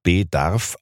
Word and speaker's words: bedarf: Hans Sachs hatte noch genannt und bedarf auf der ersten Silbe betont.